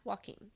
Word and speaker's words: Joaquin: With a W? Yeah. Joaquin. I mean, it's spelt with a J, but it's pronounced like a W.